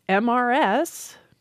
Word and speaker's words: Mrs.: The R in the spelling of Mr. seems easier to explain than the R in the spelling of Mrs., which doesn't have an R when you pronounce it anymore.